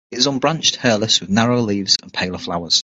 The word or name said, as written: narrower